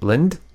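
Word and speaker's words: Lynde: It's funny how close Wolfowitz is to Paul Lynn. – Is it Lind? – Yes. – Oh, I've Paul been Lind. Saying his name wrong for the last.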